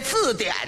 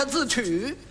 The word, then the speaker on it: 字典: 他也错了一个，这字典呢他给念成了字曲了，合着把底下两点给取消了。我给你们查查字曲。